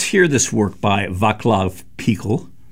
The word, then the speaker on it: Pichl: who is the Czech composer Václav Pichl. His surname is spelled P-I-C-H-L in English.